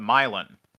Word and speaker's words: Milan: Milan, not as in the city in Italy, but Milan. This is also true of Milan, Indiana, the city best known as the setting of Hoosiers. – He also says, spelled like Milan.